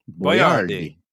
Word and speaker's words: Boiardi: His name was actually spelled B-O-I-A-R-D-I, Boyardee.